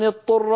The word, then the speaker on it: اضطر: يقول فمن اضطر، فينطق طاء مشددة، والعرب لا تفعل هذا، يعني يدغم الضاد في الطاء، والضاد لا تدغم في الطاء، لابد من بيان الضاد وبيان الطاء.